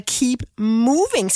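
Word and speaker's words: Keep Møving: Hun er nemlig ude med, hvad jeg kan kalde et musikalsk cirkelspark, der hedder Keep Moving, skal det udtales. Men hun staver det med ø, altså ligesom mø, ikke?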